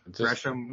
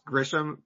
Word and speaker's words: Gresham: And also Trent Gresham, which he'll you're gonna mispronounce Grisham roughly 8,500 times.